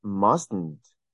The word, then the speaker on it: mustn't: That must not, mustn't there is the pronunciation of what we like to call the nasal T.